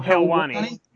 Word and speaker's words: Helwani: How would you pronounce his surname? Ariel Helwani. – That's it.